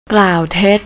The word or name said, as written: klowtet